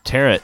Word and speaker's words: tarot: And of course, Grace Fay's famous tarot section. – Ah, yes, the tarot. – Tarot. – Rhymes with carrot.